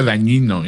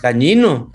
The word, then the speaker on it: Dagnino: Oye, nada más como acotación, el titular del SAT que mencionas se pronuncia Dañino, ¿eh? – Dañino. – Dañino. – No te pides Dañino. Digo, se escriba Dagnino, pero se pronuncia Dañino.